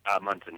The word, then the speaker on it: Munson: Munson? That's how he pronounces it, right? Munson? – Munson, yeah.